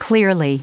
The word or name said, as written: clearly